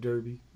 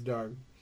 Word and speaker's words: Derby: It's spelled like Derby, but it's Derby.